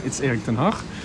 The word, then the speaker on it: Erik ten Hag: What's the mood around Erik ten Hag? And have I pronounced that correctly? What's the mood around him in the Netherlands? – He did very good. It's Erik ten Hag.